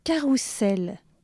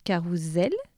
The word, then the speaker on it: carrousel: On devrait dire carousel, abasourdir, gageure, dégingandé, énologue, handball et taliatel. Alors je vous explique tout ça. Carousel et abasourdir, car un seul S entre deux voyelles se prononce Z.